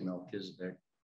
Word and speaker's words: Melchizedek: or Melchizedek is really the right pronunciation, but here in the Midwest we say Melchizedek, which is fine.